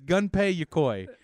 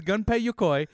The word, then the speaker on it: Gunpei Yokoi: His name is Gunpei Yokoi. – Oh, I was close. – Yeah, I say his name is Gunpei Yokoi.